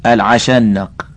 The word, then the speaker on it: العشنق: قالت الثالثة: زوجي العشنق، إن أنطق أطلق وإن أسكت أعلق. حاشية: بفتح العين والشين ونون مفتوحة مشددة وهو الطويل المستكره في طوله النحيف السيء الخلق.